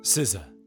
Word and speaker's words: SZA: It's SZA.